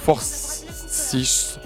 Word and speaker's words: Forsythe: Alors Molly McGinn et alors Orlight Forsis. Alors son prénom, ça s'écrit O-R-L-A avec un accent I-T-H. Le nom de famille, c'est F-O-R-S-Y-T-H-E, donc Forsis.